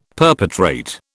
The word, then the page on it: perpetrate: British English